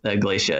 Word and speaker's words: Iglesia: Iglesia, just like Iglesia in Spanish, except for it's pronounced Iglesia in Tagalog.